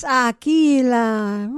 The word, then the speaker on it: Aquila: She says Aquila. That's just too gringo for me. It's Aquila. Rhymes with tequila.